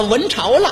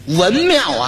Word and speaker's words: 文庙: 文朝了，一指这块匾，文庙啊，给念成文朝了，念错了一个，